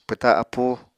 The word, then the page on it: pwta appú: Apatani